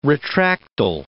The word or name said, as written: retractile